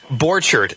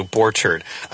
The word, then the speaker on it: Borchardt: You're really close. Borchardt. – Borchardt. Thank you, Borchardt. I was mispronouncing letters that shouldn't be mispronounced there. Borchardt.